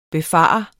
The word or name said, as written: befare